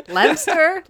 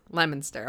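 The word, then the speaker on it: Leominster: I have pronounced it Lemster, Leo Minster, and everyone I talk to from Lemonster is like, no, it's pronounced Lemonster, okay?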